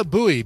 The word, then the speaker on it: Buoy: Yeah, that was Dana Bowie, B-U-O-Y, Dana Bowie. Don't spell it like David Bowie, because that's not at all what the name is.